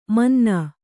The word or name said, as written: ಮುನ್ನಿ